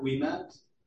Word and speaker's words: Ouimet: My name is Kirk Wiemet, spelled K-I-R-K. Last name is O-U-I-M-E-T.